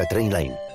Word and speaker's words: Trainline: Se pronuncia Trainline.